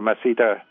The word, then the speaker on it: masseter: Và trong cái những cái cơ ngậm miệng lại có hai cái cơ quan trọng là cái cơ masseter, tiếng Mỹ thì người ta đọc là masseter,